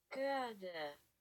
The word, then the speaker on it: Göhrde: Literally, was it G-umlaut? What? – H-R-D-E. – Göhrde. – I was right. – You were Göhrde. Right.